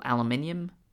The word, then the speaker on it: aluminium: aluminium superconductors. – That's aluminum and... for us here in the States. Oh, yes. Sorry, sorry. I forgot that there's a... also people that uh, pronounce it incorrectly.